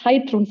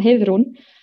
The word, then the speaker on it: Heiðrún: das ist ja nämlich nicht Heidrun, sondern Heidrun. Ähm, und der Nachname ist Seuradovter.